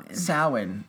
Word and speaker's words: Samhain: Samhain.